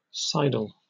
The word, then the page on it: sidle: British English